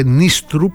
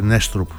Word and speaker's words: Νίστρουπ: Ο Απόστολος, όσον αφορά τον προπονητή του Παναθηναϊκού προφέρεται Νίστρουπ γιατί έχει δύο Ι. Νέστρεπ, ο Λεωνίδας τον είπε Νέστρουπ νωρίτερα, ενώ Νίστρουπ τον άκουγα.